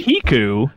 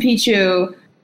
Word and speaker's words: Pichu: Were you tempted by Piku at all? Pichu? How do you pronounce that stupid thing? – Pichu, Yoshi main up in here saying Piku.